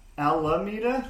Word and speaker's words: Almeda: Is it Alameda, I think? – Alameda? Like a three syllable? Because it just says A-L-M-E-D-A. – Oh, yes. – Alameda. We can twang it out if you want to.